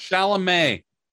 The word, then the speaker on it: Chalamet: Chalamet, see, sorry.